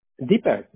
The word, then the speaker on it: Deepak: Deepak.